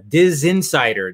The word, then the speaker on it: DisInsider: And then, of course, you are editor-in-chief of the Diz Insider. Did I say it right? The Diz Insider? Diz Insider? – It's funny. You're one of the only people I know that have said it right.